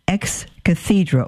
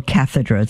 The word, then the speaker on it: ex cathedra: Is the final authority of the Church when he speaks what is called ex cathedra or cathedra. It's pronounced both ways, which means out of the chair of Peter.